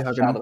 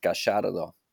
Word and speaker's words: Gallardo: In Spanien würde man Gallardo eher wie ein J aussprechen. Argentino ist Gallardo.